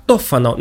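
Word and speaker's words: Tofano: В ней повествуется о богаче и ревнивце Тофано. Итальянцы произносят это имя как Тофано, но вы уж простите, я сохраню то произношение, к которому приучил меня Ерорд Масс, иначе говоря, третий участок, где мы обсуждали когда-то Декамерон с дядей Араиком.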